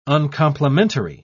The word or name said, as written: uncomplimentary